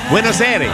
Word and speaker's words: Buenos Aires: Buenos Aires.